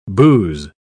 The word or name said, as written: booze